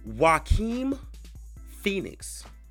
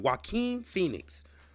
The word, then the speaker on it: Joaquin Phoenix: His name is pronounced Joaquin Phoenix. Let me say that again. Joaquin Phoenix.